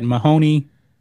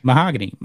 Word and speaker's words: Mahogany: The shooting occurred at Mahoney, or how do you say that? – Mahogany.